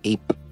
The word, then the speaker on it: EYP: And they landed on, this must be the work of EYP, which is Greek's intelligence agency, pronounced APE.